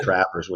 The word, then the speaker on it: Travers: There's still some people here at Vuzix that don't know how to pronounce my last name. And I thought Travers was pretty simple.